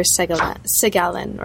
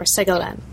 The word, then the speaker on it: Segalen: Victor Segalen, or Segalen um, depending on whether you pronounce it like an, a New Jersey Italian American, like I do, or somebody who knows what they're doing.